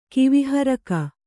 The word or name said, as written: ಕಿವಿಹರಕಿ